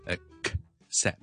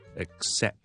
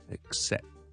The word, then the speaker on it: accept: 第一个 a 有个 K 嘅 a，OK？跟住 accept accept accept accept 个 K 系一定要喺度。但系咧，我哋又唔会将佢读成咧 accept 咁样，咁啊太慢啦。咁我 K 同 S 咧系挤埋一齐咁做嘅，听住咯，系 accept accept accept accept 个 K 系我 k 出嚟之前咧，嗱我做咗个 closure 先，定住咗，脷根定住咗，但系我唔 k 出嚟。